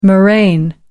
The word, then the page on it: moraine: British English